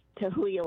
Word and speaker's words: Tahuya: In Tahuya, Washington. Am I saying it right? – Hi, yes, Tahuya, Washington.